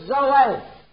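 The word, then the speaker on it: zoe (ζωή): Pronounce it zoe.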